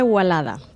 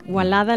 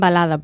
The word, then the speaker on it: Wallada: Se escribe con W y doble L, pero la pronunciación correcta es Gualada. – No Guayada sería, ¿no? – No, Guayada no. Gualada es la pronunciación correcta en árabe.